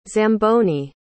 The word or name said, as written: Zamboni